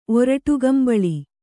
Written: ಒರಟುಗಂಬಳಿ

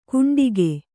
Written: ಕುಂಡಿಗೆ

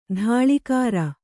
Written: ಧಾಳಿಕಾರ

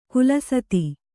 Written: ಖಾಲಸಾತಿ

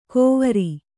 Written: ಕೋವರಿ

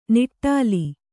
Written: ನಿಟಲ

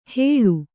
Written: ಹೇಳು

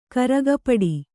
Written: ಕರಗಪಡಿ

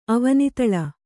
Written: ಅವನಿತಳ

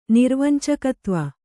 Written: ನಿರ್ವಂಚಕತ್ವ

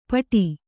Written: ಪೋಟು

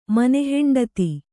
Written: ಮನೆಹೆಂಡತಿ

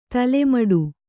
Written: ತಲೆಮುಡಿ

